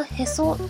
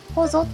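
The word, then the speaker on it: 臍: これはへそじゃなくて、ほぞって読むんだ。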